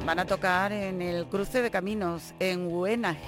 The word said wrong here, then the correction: Hueneja: A ver cómo se pronuncia esto. Hueneja, en Granada.